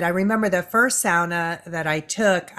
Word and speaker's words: sauna: And it's important that, you know, the image that keeps coming to heart for me is like, you know, I grew up in the sauna culture, mispronounced as sauna. Sauna, it's a Finnish word.